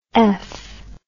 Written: eph

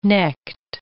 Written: necked

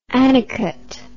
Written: anicut

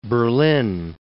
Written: Berlin

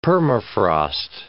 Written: permafrost

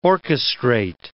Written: orchestrate

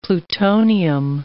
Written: plutonium

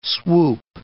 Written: swoop

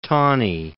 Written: Taney

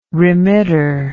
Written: remitter

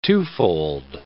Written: twofold

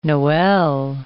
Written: noel

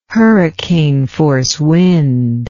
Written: hurricane force wind

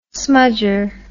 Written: smudger

